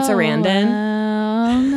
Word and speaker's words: Sarandon: Anyway, he was in this movie called Noel with Susan Sarandon.